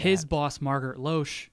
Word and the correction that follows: Loesch: Lash. – Margaret Lash? – Yeah, it's pronounced Lash.